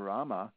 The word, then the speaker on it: Rama: And it breaks down into the sound Rama, and it's two syllables,